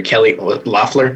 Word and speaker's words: Kelly Loeffler: Because a reminder, Kelly Loeffler, is that how you pronounce it? – Yep.